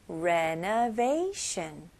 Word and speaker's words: renovation: renovate 그다음에 sh 발음으로 shun. renovation. renovation. 여기는 강세가 어디에 들어가죠? 네, they 부분에 좀 강세 주시면 좋아요.